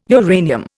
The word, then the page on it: uranium: Indian